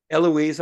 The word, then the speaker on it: Eloise: The Northern Irish women also came 3rd in the women's 50 race with, is it Elisa Crawford or Elisoa Crawford? She was 2nd overall. – Eloise, I think, is the pronunciation, yeah. – Eloise, forgive me, Eloise.